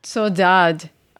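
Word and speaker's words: saudade: She actually has the, and tell me if I'm saying this correctly, Catherine, Sudage? Did I say it right? – That's the Brazilian pronunciation. – I said it wrong. – But yeah, my father was actually from the Azores, and he would say Sodad.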